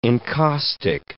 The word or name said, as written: encaustic